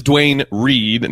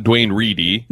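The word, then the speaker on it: Duane Reade: And it is Dwayne Reed, not Dwayne Reedy. I could have sworn I'd heard it pronounced the other way, but it is just Dwayne Reed.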